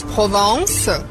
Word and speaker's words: Provence: A gente diz Provence, mas se escreve Provence.